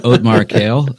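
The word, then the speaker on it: Othmar Keel: So Otmar Kail, but it's Othmar. K-E-E-L.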